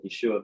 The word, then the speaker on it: Yeshua: and so when a Hebrew says Yeshua's name really fast, it just sounds like Yeshua because it's pronounced Yeshua. The middle ha is kind of really, really missable in there when you say it really fast like that.